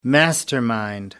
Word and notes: mastermind: American English

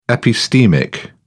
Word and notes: epistemic: British English

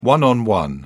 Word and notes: one-on-one: British English

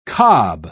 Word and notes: kob: British English

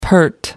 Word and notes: pert: American English

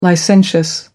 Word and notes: licentious: American English

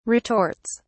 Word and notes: retorts: British English

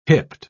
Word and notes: hipped: British English

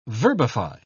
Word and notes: verbify: British English

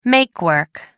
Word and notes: make-work: American English

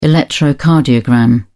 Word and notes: electrocardiogram: British English

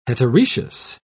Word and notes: heteroecious: British English

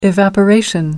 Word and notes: evaporation: British English

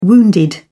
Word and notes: wounded: British English

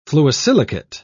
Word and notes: fluosilicate: British English

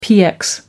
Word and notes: px: American English

